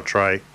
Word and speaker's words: Tare: I think it's Tear Duven. – Oh, Trey. Trey Dubin. – Trey? It was written here as Tear, so. – Right. – I don't know. T-A-R-E. – Yeah, Trey. Dubin.